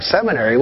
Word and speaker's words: seminary: It's like, seminary? What's that? It rhymes with cemetery.